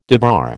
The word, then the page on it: debar: American English